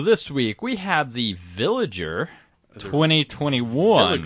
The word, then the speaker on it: Villiger: Yes, that always makes me sick, because, yeah, it's spelled like Villager, but instead of an A, it has an extra I.